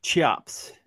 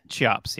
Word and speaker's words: Cheops: I don't know how to pronounce this, the Cheops pyramid. – Cheops. – Cheops, yeah.